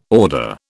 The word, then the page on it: order: British English